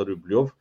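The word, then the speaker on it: Rublev: Uh, la fel cum se pronunță și Rubliov, adică apropo de discuții din astea la tenis, e o mare dispută pentru că toți comentatorii Eurosport pronunță Rubliov, ca și al cheamă pe băiat.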